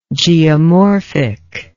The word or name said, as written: geomorphically